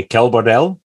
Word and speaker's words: Quel Bordel: So I found out that you were recently in a band called, if I say this right correctly, Quel, Bordel.